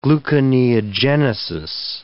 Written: gluconeogenesis

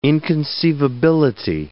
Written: inconceivability

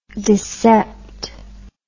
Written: discept

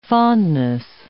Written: fondness

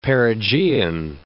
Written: perigean

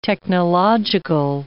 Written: technological

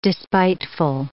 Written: despiteful

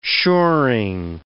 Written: shoring

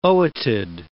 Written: ootid

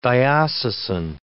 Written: diocesan

